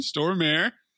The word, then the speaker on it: Stormare: Stormare. – Stormare. – What did you call him? – Stormage. Apparently there was a typo in my notes. – We need a Stormage. – Because it's spelled Stormage on here. So I just said Stormage.